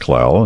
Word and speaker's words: Clell: C-L-E-L-L. My father's name was Ernest Clell, and I'm Ronald Clell. – C-L-E-L-L.